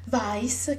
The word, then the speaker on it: Vice: infatti il titolo è Vice, che noi pronunciamo chiaramente all'inglese, però sai, vice, quindi si capisce insomma di che cosa parla.